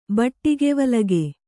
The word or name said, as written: ಬಟ್ಟಿಗೆವಲಗೆ